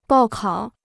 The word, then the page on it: 报考: bào kǎo